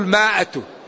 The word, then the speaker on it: مائة: فإذا قرأ القارئ وهو لم يعرف رسم المصحف ولم يكن عنده شيخ، يقول قالوا، يقولها قالوا، ويقول إن يكن منكم مائة يقول مائة. هي ما هي مائة، هي مائة، لكن في الإملاء العربي الميم تزاد ألف ويجعل عليها في المصحف دائرة لأنها لا تنطق.